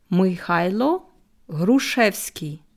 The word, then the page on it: Mychajlo Hruschewsky: slow